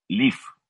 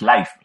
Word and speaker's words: Live: Es que, a ver, eh, es que es Live la pronunciación en inglés no es life, life sería L-I-F-E.